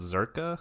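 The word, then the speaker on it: Czerka: Is basically run the princess who's going to be a queen is basically just a figurehead for this corporation, which I pronounce Zerka. – Yeah, I was saying it's Zerka, yeah. – It's got a C in front of it, so...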